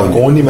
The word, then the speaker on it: Lacaune: Fala Lacone, mas se escreve Lacaune.